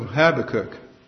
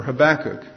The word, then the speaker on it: Habakkuk: Instead, I'll just launch right into my sermon, and it's about the book of Habakkuk, or Habakkuk. It can be pronounced either way.